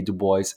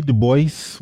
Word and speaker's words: Du Bois: Yes, W.E.B. Du Bois, well, that's how he pronounced his name. Of course, the French pronunciation is Dubois, but he went by W.E.B. Du Bois.